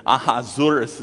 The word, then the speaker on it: Ahasuerus: Ahasuerus is actually one of three ways to pronounce his name. And the first one, which is actually the way the Hebrews pronounce it, was so complicated that I'm like, I'm going to stick with English pronunciation of his name.